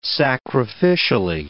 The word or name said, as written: sacrificially